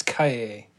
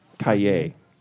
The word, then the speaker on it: Cahiers: It's Cahiers. – Cahiers, yeah. I got to remember that.